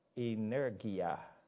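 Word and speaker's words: energeia: The Greek word translated by power or working here sounds a lot like the English word that derives from it. Let me pronounce it in Greek. Energia.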